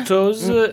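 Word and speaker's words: Toews: Ouais, je ne sais pas comment on le prononce. – Voilà, Toze, et c'est ce qu'elles disent.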